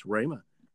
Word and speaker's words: Rhema: It's Rhema. – Yes, that is the correct pronunciation. I was pronouncing it wrong.